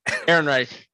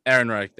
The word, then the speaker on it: Ehrenreich: Can't pronounce his name. Alden. – Ehrenreich. – Aaron Reich. There. Okay, that sounds a lot easier than you said.